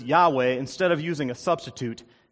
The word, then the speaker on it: Yahweh: When the word is pronounced, it's pronounced Yahweh.